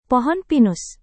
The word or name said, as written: pohon pinus